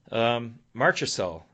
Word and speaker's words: Marchessault: Marchessault, is that how you pronounce it? – Yeah, the ex-Tampa guy.